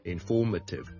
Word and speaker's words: informative: 個音節嘅所以就會讀呢 form,form,form,而後面個 may 呢我哋就唔讀 a 而讀 a 好輕嘅 m so informative informative informative